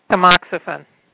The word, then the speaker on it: Tamoxifen: and this hard-to-say word, Taxotemol, how do you say that? – Tamoxifen.